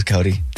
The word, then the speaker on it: Coty: And he said, I bet it was Cody. – C-O-T-Y. – The what? – Not Cody. It's probably Cody. I mean, it's spelled different, yes, but I bet it was Cody.